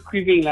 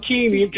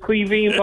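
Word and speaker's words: Caoimhín: You might call him Keeney. I said, no, it's Queeveen, boy.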